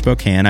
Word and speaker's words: Spokane: It is pronounced Spokane.